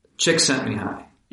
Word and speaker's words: Csikszentmihalyi: This is an idea most famously associated with Csikszentmihalyi is one pronunciation of that formidable last name. – Chick sent me high.